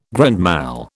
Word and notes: grand mal: British English

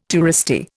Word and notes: touristy: Indian

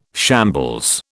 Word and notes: shambles: British English

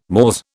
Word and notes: mores: British English

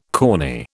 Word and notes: corny: British English

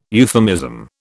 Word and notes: euphemism: British English

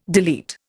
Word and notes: delete: Indian

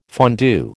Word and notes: fondue: American English

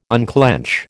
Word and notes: unclench: American English

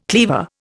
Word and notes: cleaver: Indian

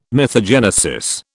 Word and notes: mythogenesis: British English

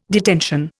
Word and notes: detention: Indian

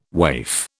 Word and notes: waif: British English

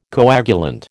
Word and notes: coagulant: American English

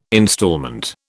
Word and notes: instalment: British English

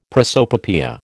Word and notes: prosopopoeia: American English

